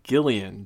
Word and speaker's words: Gillian: Her name is pronounced Gillian Jacobs, which is kind of unusual, but definitely unique.